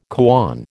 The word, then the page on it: koan: American English